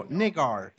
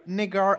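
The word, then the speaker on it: Niggar: No, nigar, it's pronounced, but you can pronounce this as you want. Nigger is fine, though. It's a European name, Polish one.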